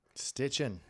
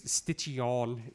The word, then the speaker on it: Stitchin: That's how you pronounce that, buddy. – Yes, Stitchin. It's Stitchion is what everybody wants to pronounce it. They want to put that. – Stitchion. Yeah. Everybody wants to make it French. – Yeah, and then I'm just like, no, it's just Stitchin.